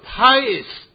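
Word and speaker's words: pious (παῖς): 这个希腊字呢，它念作 pious 这个字。 – 那个希腊字呢，就是读作 pious。 – 这个 pious 这个字呢，它正好有两个解释，就是说翻译的话呢，可以翻成儿子，也可以翻成奴仆。 – 那个 pious 那个字呢，也都可以翻作奴仆或者儿子。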